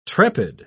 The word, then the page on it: trepid: British English